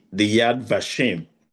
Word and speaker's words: Yad Vashem: We spent the night in Jerusalem, and our journey began from visiting the Holocaust Museum, the Yad Vashem, if I get that correctly.